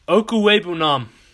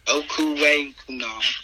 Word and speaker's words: Okwuegbunam: Okwuegbunam. – Okwuegbunam.